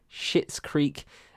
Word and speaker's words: Schitt's Creek: Comedy series, we've got Barry, Fleabag, The Good Place, The Marvelous Mrs. Maisel, Russian Doll, Schitt's Creek, pronounced S-C-H-I-T-T, and Veep.